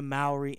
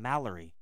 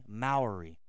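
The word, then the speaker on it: Māori: I have a kind of a Philly, New Jersey accent, and I added an L. So the whole time I was saying Mallory. It's really Maori, okay?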